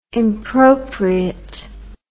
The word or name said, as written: impropriate